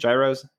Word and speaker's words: gyros: Gyros, yep.